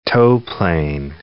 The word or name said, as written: towplane